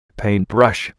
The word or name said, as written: paintbrush